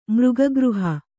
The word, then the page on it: ಮೃಗಗೃಹ: Kannada